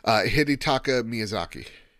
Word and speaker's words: Hidetaka Miyazaki: Hidetaka Miyazaki.